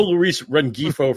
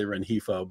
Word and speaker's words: Rengifo: You know, no Luis Rengifo from you. You're going to say Rengifo. But, yes, it's Luis Gil is how you say it. G-I-L is how you spell it.